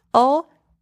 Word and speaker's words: aux: If the name of the place is plural, use O, A-U-X. Oh, the X is silent at the end, by the way.